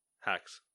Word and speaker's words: Hexe: H-E-X-E. – Hex.